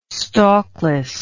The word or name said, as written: stalkless